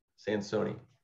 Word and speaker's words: Sansone: Sansone.